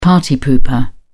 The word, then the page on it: party pooper: British English